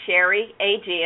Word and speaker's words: Sherry AG: And on both of those channels, my name is Sherry A-G, and I'll spell it, S-H-E-R-R-Y-A-G.